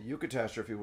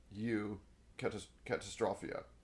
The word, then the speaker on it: eucatastrophe: At the end, he introduces an idea called the Eucatastrophe, which is Greek for the good catastrophe, Eucatastrophia. So don't ask me to parse EU. – Yeah. Yes, pronounced U. Like, well, there's a bunch of things like the Eucharist, right?